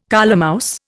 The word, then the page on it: colemouse: Indian